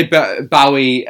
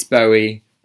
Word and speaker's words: Bowie: Some people say Bowie, it's Bowie.